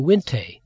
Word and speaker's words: Winkte: where she learned the tradition of the Winté, that's spelled W-I-N-K-T-E, but pronounced Winté.